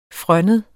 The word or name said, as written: frønnet